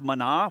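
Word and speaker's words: manah (מן): In the Hebrew language, it's pronounced manah, which literally means, what is it?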